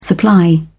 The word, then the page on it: supply: British English